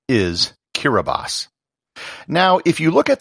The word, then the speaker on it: Kiribati: The letters T-I form an S sound in the Gilbertese language. Hence, what should be Kiribati is pronounced Kiribati. However, it gets far better than just the name of the country.